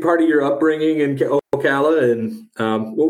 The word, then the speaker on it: Ocala: And I want to go back to, and I hope I'm saying this right, Ocala, Florida. Is that right? – Ocala. – Did I say that right? – Claps for that. I am impressed. – Okay. – Yes, you said it right.